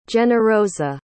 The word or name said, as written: generosa